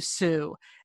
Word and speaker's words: Tsui: It's called Why We Swim by Bonnie Sue. And Sue is spelled T-S-U-I.